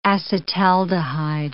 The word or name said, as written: acetaldehyde